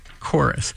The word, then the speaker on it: chorus: Our challenge to you is to come up with five other words that use CH as their first two letters, but in their pronunciation, the CH is pronounced as a K, just as in chorus and charisma.